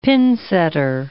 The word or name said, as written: pinsetter